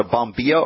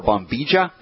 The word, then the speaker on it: bombilla: I'm holding up my teacup, and I've got what's known as a bombilla or bombija, depending on how you pronounce that.